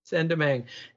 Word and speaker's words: Saint-Domingue: but was called back in the day Saint-Domingue, or how do you say it best? – Saint-Domingue.